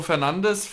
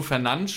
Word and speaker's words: Fernandes: Aber an sich so, in der Offensive, jetzt gerade auch mit Bruno Fernandes von, oder Bruno Fernandes spricht man ihn, glaube ich, aus, von Manchester United mit einer wahnsinnig guten Saison.